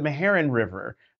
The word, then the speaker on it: Meherrin: And so now I would hear about, although my grandmother would mispronounce it, she'd leave off the end. It was always the Meherrin, but, and just talking about the Meherrin River, but it's the Meherrin River, there, in that part of North Carolina.